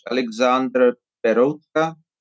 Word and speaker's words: Alexander Peroutka: When I pronounce it Czech, it sounds slightly different. But I think in English, Alexander Peroutka, that's the name I usually pronounce.